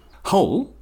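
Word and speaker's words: whole: Well, it is in fact two words because hole, as in complete, spelt with a WH, is pronounced in exactly the same way as hole with an H, which is an absence of matter.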